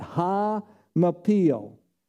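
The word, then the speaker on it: Ha-Mapil (הַמַּפִּיל): That's how you pronounce it, Ha-Mapil.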